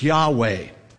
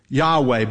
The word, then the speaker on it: Yahweh: It's generally agreed that you pronounce this Yahweh, Yahweh, by just pronouncing the consonants.